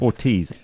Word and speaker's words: Ortiz: Ortiz. O-R-T-I-Z. – O-R-T-I-Z.